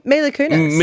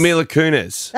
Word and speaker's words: Mila Kunis: Well, did I say the name correctly before? – Mila Kunis. – Mila Kunis. – That is Mila Kunis.